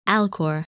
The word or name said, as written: Alcor